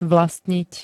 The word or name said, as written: vlastniť